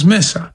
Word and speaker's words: Messa: Misa asked, in Italian, the Latin word Misa is also feminine gender, as Messa. The E is pronounced as in men, Messa.